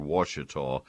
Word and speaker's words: Ouachita: And uh, his his blackberry of choice now is one called Ouachita, and that starts with O U, and um, I'd have I got to look that up every time.